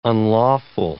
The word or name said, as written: unlawful